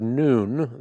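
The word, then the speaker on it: noon (ن): So this symbol, the 14th letter of the Arabic alphabet, we use the word noon, that's how you pronounce it, to describe this letter.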